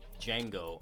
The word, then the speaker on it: Django: Follow me, Django underscore nerd. D is silent. D-J-A-N-G-O underscore nerd.